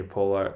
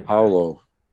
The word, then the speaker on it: Paulo: And he was actually saying that you know we, we obviously say Junior Paulo, and then there's there's Paulo, but yeah, that the the P is is a B sound. So apparently the correct pronunciation, according to uh, Junior himself last night, was uh, Junior Paulo. So it's more of a oh, no. – B sort of sound for the P.